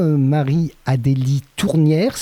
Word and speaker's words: Marie-Adélie Tournière: Alors bonjour Marie-Adélie Tournière, c'est bien comme ça qu'on prononce votre nom, j'espère. – Tout à fait.